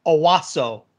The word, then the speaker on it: Onwuasor: How was that pronunciation, by the way? Onwuasor. – You put in the N in there. There's no N. It's Owasso.